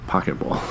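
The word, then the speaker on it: Poké Ball: I'm also like how a Pokeball is a Pokeball. – Yeah. – Did I even say that right? – You absolutely did.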